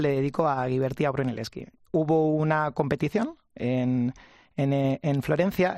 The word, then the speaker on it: Brunelleschi: Brunelleschi, ¿cómo se dice? – Brunelleschi. – Brunelleschi fuera la estructura más grande y también la más alta de Europa en su época.